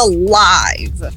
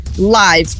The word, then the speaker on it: live: Agora, tanto como adjetivo como advérbio, ela vai ser pronunciada live. Live, com long I sound. Agora, em se tratando de verbo, é escrito igual, mas o verbo viver ou morar, ele é live, que aí já vai ser o short E sound. Então, vai ser live.